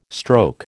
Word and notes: stroke: American English

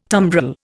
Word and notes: tumbrel: Indian

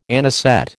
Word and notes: anisette: American English

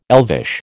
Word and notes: elvish: American English

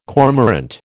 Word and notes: cormorant: American English

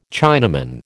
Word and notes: Chinaman: American English

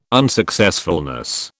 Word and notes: unsuccessfulness: British English

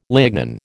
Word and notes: lignin: American English

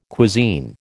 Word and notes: cuisine: American English

